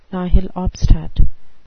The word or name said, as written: nihil obstat